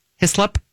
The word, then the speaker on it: Hislop: Um, there is is it Hislop? Is that how you say it? Hislop? Yeah uh, which is a quinine tasting oh, like yeah.